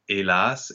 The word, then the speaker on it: hélas: Yeah, well, another very common word in poetry is the word for alas, so hélas in French. So again, notice that the H is not pronounced, but in this case, it's not aspirate.